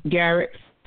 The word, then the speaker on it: Garriques: Garriques.